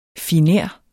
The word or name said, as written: finer